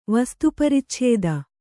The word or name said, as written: ವಸ್ತುಪರಿಚ್ಛೇದ